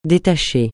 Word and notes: détacher: francais

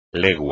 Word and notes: legua: espagnol

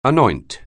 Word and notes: anoint: anglais

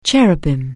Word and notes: cherub: English

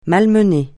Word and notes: malmener: francais

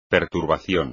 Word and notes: perturbación: Spanish

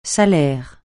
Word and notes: salaire: francais

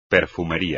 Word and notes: perfumería: Spanish

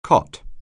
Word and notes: cot: anglais

